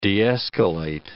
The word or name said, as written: de-escalate